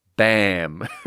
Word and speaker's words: Bomb: Ah, you know how you pronounce it? The written answer on Google, just written in, I don't even need to press play. B-A-A-M. Bam.